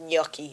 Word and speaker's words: gnocchi: but I think it's pronounced gnocchi in Italian.